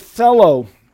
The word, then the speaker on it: thelo: It's the word thelo. In Greek, that is the word for will. T-H-E-L-O, long O here, means volition.